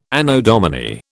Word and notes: Anno Domini: British English

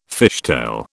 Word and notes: fishtail: British English

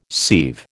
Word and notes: sieve: American English

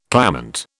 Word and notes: clamant: British English

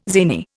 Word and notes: zany: Indian

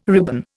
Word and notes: ribbon: Indian

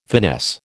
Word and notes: finesse: American English

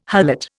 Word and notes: harlot: Indian

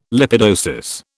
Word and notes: lipidosis: British English

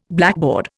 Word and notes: blackboard: Indian

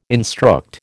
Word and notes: instruct: American English

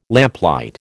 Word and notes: lamplight: American English

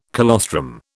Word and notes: colostrum: British English

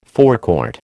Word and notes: forecourt: American English

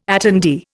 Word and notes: attendee: Indian

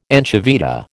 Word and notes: anchoveta: American English

